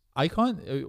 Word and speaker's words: Icon: But I think find me, like I said, wherever, Alex Icon. – Is that your real last name? Icon. – It is. So is that how it's actually pronounced? – Icon. Well, some people say Econ, right?